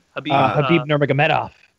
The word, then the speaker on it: Khabib Nurmagomedov: Khabib Nurmagomedov. – Nice pronunciation. I'm impressed.